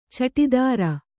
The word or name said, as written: ಚಟಿದಾರ